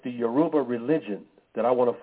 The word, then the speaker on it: Yoruba: Yoruba. Yoruba is spelled Y-O-R-U-B-A.